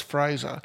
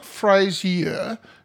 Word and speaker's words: Fraser: Well, last week we had Alice Fraser. – Her trilogy. – And it's not, yeah, it's not Frasier as I was pronouncing it. It's just Fraser.